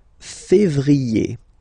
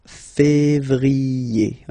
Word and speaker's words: février: Then comes février, février, okay, remember when you have this accent aigu on the top of then you pronounce it E, okay?